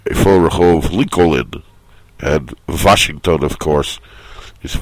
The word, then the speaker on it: Lincoln: Just know here that they don't accept the silent L in Lincoln.